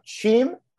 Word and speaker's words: Cheam: Now, I'm going to try to pronounce this correctly. Cheam? – Yeah, you got it.